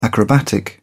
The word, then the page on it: acrobatic: British English